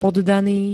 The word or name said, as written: poddany